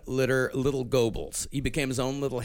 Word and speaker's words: Goebbels: Well, it's but I mean the actual pronunciation is even cuter. Goebbels.